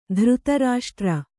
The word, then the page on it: ಧೃತರಾಷ್ಟ್ರ: dhřta rāṣṭra